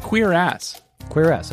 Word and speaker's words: Cuirass: Cuirass. – Wait. Let me Google. – Okay. Sorry. – Here we go. Pronunciation guide. Mm-hmm. Queer ass.